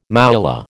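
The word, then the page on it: mouillé: American English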